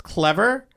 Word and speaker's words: Clevr: It's pronounced clever, but it's spelled C-L-E-V-R.